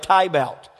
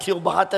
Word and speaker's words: Tiebout: If you don't know, my last name is spelled T-I-E-B-O-U-T. So it's like Tybout. And I was talking to somebody this week, and they've known me for 12 years, and they butchered it again. It's like, Pastor Tilbot. I was like, no, I'm not Tilbot.